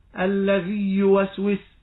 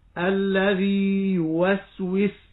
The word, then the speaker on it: الذي يوسوس: وبالمثال يتضح المقال، عندما تقرأ الذي يوسوس، الذي هنا مد طبيعي وبعده يوسوس، الياء هنا ياء محرك، فتجد القارئ هنا يقول الذي يوسوس فيدغم الياء في الياء، فنقول له لا، مكن مكن النطق بياء المد بمقدار حركتين، فتقول الذي يوسوس الذي يوسوس، تمكن المد.